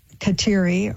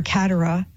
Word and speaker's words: Kateri: But eventually there would be priests that would come in, help form her in the faith, and she would be baptized Kateri or Katera, as some pronounce it, named after the great Saint Catherine of Siena.